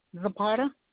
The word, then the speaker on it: Zapata: Lucia, L-U-C-I-A, Zapata, Z-A-P-A-T-A. – You said that first name was L-U-C-I-A? – Yes.